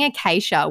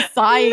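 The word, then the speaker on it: açaí: Someone sent her pronunciation through and they were saying acacia, which my ex-boyfriend actually used to do. He'd be like, can I get an acacia bowl? And I'm like, it's an acai bowl.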